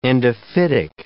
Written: endophytic

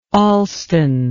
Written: Alston

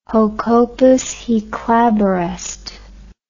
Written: hoc opus hic labor est

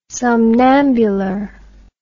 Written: somnambular

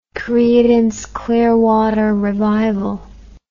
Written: creedence clearwater revival

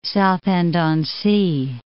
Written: Southend-on-Sea

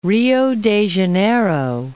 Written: Rio de Janeiro

